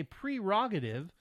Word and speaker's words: prerogative: How would you pronounce prerogative? It's if you say prerogative, you'd be on my wavelength. If you said per for the first syllable, I would have to disagree.